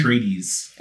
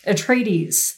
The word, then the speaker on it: Atreides: Atreides. – Atreides, thank you.